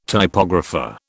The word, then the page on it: typographer: British English